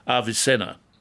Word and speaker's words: Avicenna: He was called, in fact, Avicenna, or that's how we pronounce his name, A-V-I-C-E-N-N-A.